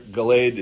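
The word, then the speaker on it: Galeed: I put it in your notes there in verse 47 and 48, Galed, because Gal means heap, and Ed is a witness. So you put them together, Galed is how it's pronounced, the heap of witness.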